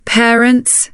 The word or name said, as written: parents